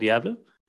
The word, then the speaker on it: Gävle: So it's in a city perhaps named Gävle. Gävle. – Yeah. That's a very accurate pronunciation. It is a city, and it's called Gävle.